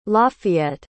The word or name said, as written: Lafeyette